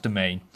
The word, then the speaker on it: demesne: Now, if you see that in writing, if you see that on your exam, it's a weird spelling, domain. So it's royal and then D-E-M-E-S-N-E. It's sort of dimension, and all right, it's a bit of a weird spelling, but it's pronounced domain.